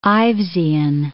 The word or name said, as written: Ivesian